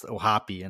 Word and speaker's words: O'Hoppe: Logan Ohapi. I still can't get over that. It's actually pronounced Ohapi and not Ohap, but it is. It's Ohapi.